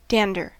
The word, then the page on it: dander: American English